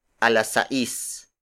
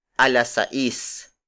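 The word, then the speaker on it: a las seis: When used to tell time, we refer to 6 o'clock as a las sais. A las sais.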